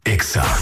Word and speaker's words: Exa: No se dicen éxitos, se dice EXA. – En todas partes. – En todas partes. Ponte EXA FM 89.9.